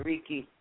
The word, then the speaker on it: Reiki: Am I pronouncing that right? Reiki? – Yes, you are Reiki.